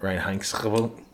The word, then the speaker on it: Reinheitsgebot: Reinheitsgebot.